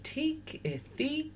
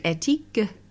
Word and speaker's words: Ethique: And I mean, it's French for ethical, and it's technically ethique. So no one technically says it right. And so it's fine. People say ethique and ethnique.